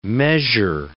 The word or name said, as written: measure